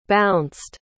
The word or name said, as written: bounced